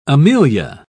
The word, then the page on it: amelia: British English